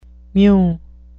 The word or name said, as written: mew